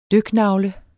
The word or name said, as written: DYKNAGLE